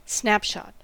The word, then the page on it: snapshot: American English